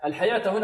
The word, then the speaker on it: الحياة: بل تؤثرون، الراء هنا مفخمة، بل تؤثروا، رو، رو، بل تؤثرون الحياة الدنيا، الحياة هنا رسمت بماذا؟ رسمت بالواو وتقرأ بالألف، ولذلك هي في الصورة واو لكن في النطق ماذا؟ ألف، ولذلك علماء الضبط ماذا وضعوا فوق الواو؟ وضعوا ألف، ألفا خنجرية، قال ألف خنجرية هكذا للدلالة على أن الواو تنطق ماذا؟